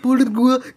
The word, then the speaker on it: Bulgur: dass es mit Burgursch gemacht Sorry, weil ich falsch ausgesprochen habe. Dass es eigentlich mit Bulgur gemacht wird.